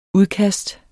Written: udkast

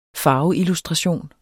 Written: farveillustration

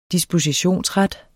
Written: dispositionsret

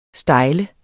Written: style

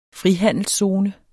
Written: frihandelszone

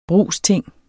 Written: brugsting

